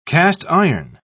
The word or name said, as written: cast-iron